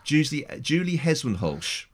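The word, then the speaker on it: Julie Hesmondhalgh: Now we had this conversation before we started, and I've learned the proper way to pronounce her name, Julie Heswenholsch. – You just got Julie wrong.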